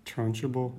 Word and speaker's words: Trunchbull: how do you say her name? – Trunchbull. – Yeah.